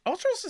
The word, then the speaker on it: Ultros: I was like, have I been saying it wrong this entire time? You're going to make me look like a fool? – No. You're good. You're good. Ultros is pretty good.